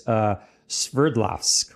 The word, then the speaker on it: Sverdlovsk: I don't speak Russian, but it's Sverdlovsk, which I believe is. – That sounds right.